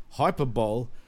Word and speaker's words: hyperbole: Reality has become legendary and Reigns has superseded Hyperbole. He wants to tell Roman hyperbole. – Something. Oh, is that how it's pronounced? Hyperbole? – Yeah. Yeah, hyperbole, yeah.